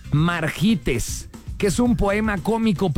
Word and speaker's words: Margites: Margites. M-A-R-G-I-T-E-S.